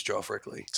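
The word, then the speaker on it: Geoff Rickly: I'm not saying that's not a thing, but his specifically is Joff Rickley.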